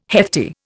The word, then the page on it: hefty: Indian